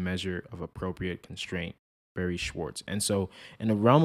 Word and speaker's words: Schwartz: Schwartz.